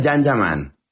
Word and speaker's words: Can Yaman: Se escribe Can Yaman, se pronuncia Jan Yaman.